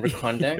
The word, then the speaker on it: Recondo: Yeah, rhymes with Secondo. – And Recondo.